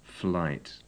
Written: flight

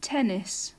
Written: tennis

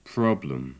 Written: problem

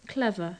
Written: clever

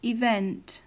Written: event

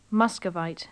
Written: muscovite

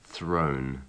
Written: thrown

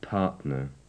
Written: partner

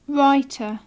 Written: writer

